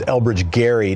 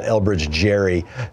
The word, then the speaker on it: Elbridge Gerry: And for the call for gerrymandering, or as my dad would say, gerrymandering, and he would admonish me to say his name was Elbridge Gerry, not Elbridge Jerry.